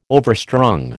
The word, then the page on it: overstrung: American English